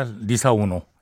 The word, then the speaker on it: Lisa Ono: 어, 이, 저, 포르투갈 발음으로는 이름이 리자오누라는데요. 리자오누. 우리는 뭐 다 리사 오노.